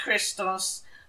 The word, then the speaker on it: Christos: If you say Christos. – Christos. – It's pronounced Christos, but the first letter of it is an X.